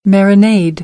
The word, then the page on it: marinade: American English; ɛ